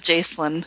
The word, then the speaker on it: Jacelyn: How do you say this guy's name? Jacelyn? Is that how you guys say it? – I was thinking of Jacelyn. – Yeah, that's how it is.